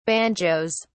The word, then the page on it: banjoes: British English